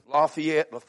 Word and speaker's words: Lafayette: Some years ago, I was preaching down at a place called, I pronounce it, Lafayette, Georgia.